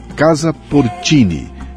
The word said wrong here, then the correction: Porcini: Se escreve Casa Porcini, com C.